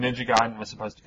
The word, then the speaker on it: Ninja Gaiden: It's pronounced Ninja Gaiden. – Ninja Gaiden.